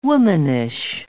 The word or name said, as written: womanish